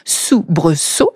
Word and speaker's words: soubresaut: Eh ben idem, ici, on entend deux fois le son S, soubresaut, et pourtant le deuxième S est entouré de deux voyelles.